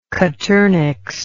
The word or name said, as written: coturnix